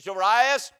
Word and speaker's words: Jairus: I pronounce his name Jairus. When you preach, you pronounce it however you want to.